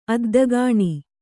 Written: ಅದ್ದಗಾಣಿ

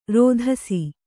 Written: ರೋದಿಸು

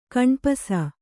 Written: ಕಣ್ಪಸ

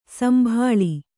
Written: ಸಂಭೋಳಿ

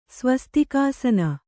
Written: ಸ್ವಸ್ತಿಕಾಸನ

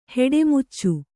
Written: ಹುಟ್ಟುಮಚ್ಚೆ

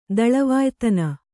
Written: ದಳವಾಯ್ತನ

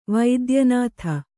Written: ವಾತಾಯನಿತ

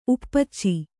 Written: ಉಪ್ಪಚ್ಚಿ

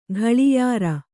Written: ಘಳಿಯಾರ

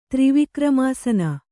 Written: ತ್ರಿವಿಕ್ರಮಾಸನ

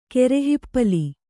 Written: ಕರಿಹಿಪ್ಪಲಿ

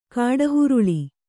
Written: ಕಿಡಿಹೊರಳಿ

